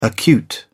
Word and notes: acute: British English

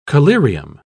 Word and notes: collyrium: British English

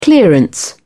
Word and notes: clearance: British English

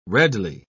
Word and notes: redly: British English

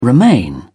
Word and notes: romaine: British English

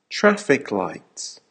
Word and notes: traffic lights: American English

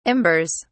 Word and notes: embers: British English

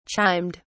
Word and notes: chimed: British English

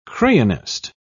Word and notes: crayonist: British English

